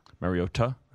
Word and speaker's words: Mariota: Mariota? – That's correct.